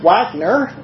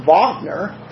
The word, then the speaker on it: Wagner: Until I found out the German composer's name is actually pronounced, looks like Wagner to me, but it's pronounced Wagner. The W becomes a V in the way the Germans pronounce it.